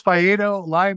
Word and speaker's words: Fadeo: This thing, it's Fadeo, rhymes with Laredo.